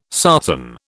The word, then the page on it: sarsen: British English